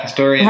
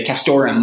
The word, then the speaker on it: Castoreum: Oh, yeah. Castoreum. – How do you pronounce it? Castoreum. Okay. – Castoreum. – Yeah. – That's what I say. Other people pronounce it differently. You know, I heard people say castorum without the E. That's how a lot of trappers say it.